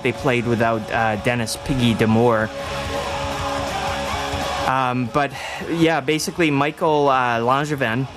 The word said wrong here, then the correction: Michel Langevin: or Michel Langevin, for all of you hardcore Frenchies out there that would be all over me for mispronouncing his name,